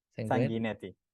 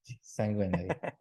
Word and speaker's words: Sanguinetti: And I'm going to butcher her name. Alessandra Sanguinetti? – Sanguinetti. – Sanguinetti. I'm so bad at names.